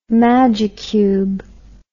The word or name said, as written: magicube